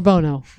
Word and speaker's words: Bono: Or Bono. Right. Right. Excuse me. Accent on the wrong syllable.